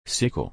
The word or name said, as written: sickle